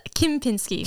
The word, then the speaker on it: Kimpinski: Okay, how did I mess it up? – It's Kimpinski. – Kimpinski. Yeah. Okay. Yeah. Kimpinski. Thank you.